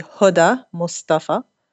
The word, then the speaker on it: Hoda Mostafa: Well, the Arabic pronunciation would be Hoda Mostafa, but Hoda's okay.